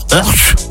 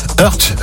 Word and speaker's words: Hoerdt: Ça s'écrit H-O-E-R-D-T. Je veux dire Earth. Ouais, pas mal. Earth,